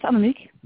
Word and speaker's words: Annemiek: It's perfect. It's Annemiek. Really.